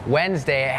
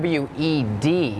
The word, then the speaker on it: Wednesday: โล่งออกเหรอลูกโล่งใช่ไหมเห็นแล้วถอนหายใจเฮือกว่าเริ่มเลยก่อนเริ่มอะไรก็พูดถึงคำว่าอะไรน้องๆจำได้ไหมคะ Silent letters ใช่ไหมคะนั่นก็คือตัวอักษรที่ไม่ออกเสียงแต่อย่างคำนี้ค่ะเป็นคำหนึ่งที่มี silent letters Wednesday has that D W E D it's not wed Wednesday, Wednesday, D is silent.